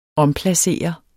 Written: omplacere

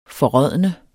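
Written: FORRÅDNET

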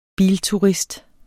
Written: bilturist

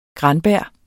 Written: Granberg

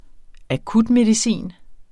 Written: Akutmedicin

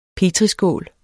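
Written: petriskål

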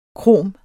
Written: krom

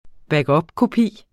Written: backupkopi

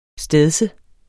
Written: stedse

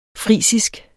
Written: frisisk

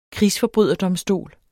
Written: krigsforbryderdomstol

